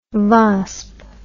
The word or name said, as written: vasp